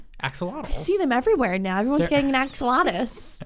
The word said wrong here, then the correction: axolotl: Axolotl. – Axolotl. – I just said it. You said it wrong again.